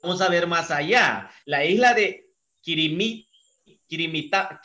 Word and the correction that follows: Kiritimati: Kirimi, se escribe Kiritimati.